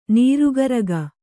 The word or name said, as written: ನರಗುರಿಗ